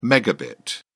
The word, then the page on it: megabit: British English